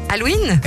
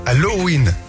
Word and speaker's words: Halloween: Halloween ? – Eh non, Halloween. On prononce le haut quand même.